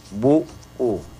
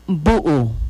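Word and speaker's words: bu'u: Bu U. Es la B, la U, el apóstrofo y la U. – Nuevamente. En Yucatán se pronuncia Bu U.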